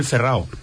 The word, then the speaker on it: Ferrao: El Ferrao es el apodo, no es el Ferrao, es el Ferrao, con acento en la O.